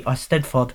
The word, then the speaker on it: Eisteddfod: Thank you to, I'm meant to be the producer, to the man who actually knows how to say it properly, Eisteddfod, the Welsh Festival.